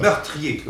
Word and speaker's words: meurtrier: Meurtrier. Oui. Et actuellement, c'est trois syllabes.